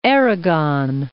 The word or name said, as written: Aragon